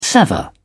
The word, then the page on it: sever: British English